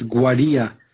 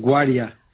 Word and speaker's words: Guaria: It's Guaria. – Guaria. Yeah, sorry. – Yeah. Not without, it doesn't carry an accent on the. It's actually pronounced Guaria.